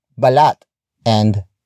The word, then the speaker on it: balatan: If the an suffix is added to a root word that is stressed at the last syllable, the stress shifts to the suffix, as demonstrated in balat and balatan.